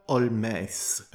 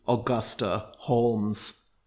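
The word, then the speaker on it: Holmès: It is a composition by the Irish/French composer Augusta Olmès. Again, an alternative pronunciation of this person's name would be Augusta Holmes, but being French, she goes by Augusta Olmès.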